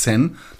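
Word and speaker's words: Chi (千): Und dieses Zeichen ist eigentlich ein Chi, ja, Chi wird es ausgesprochen, aber man kann es auch als Kanji lesen und dann heißt es Sen, das heißt 1000.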